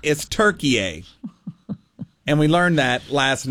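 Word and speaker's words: Türkiye: So Turkey A is spelled T-U-R-K-I-Y-E. And there's those two dots over the U. All right? And it's pronounced Turkey A.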